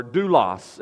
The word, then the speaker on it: doulos: The word serving is the same root as the word servant or slave or bond servant, and perhaps you've heard it before, doulos is how I pronounce it in the Greek.